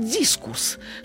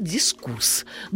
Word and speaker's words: дискурс: Говорят дискус, хотя строго говоря, надо говорить дискурс.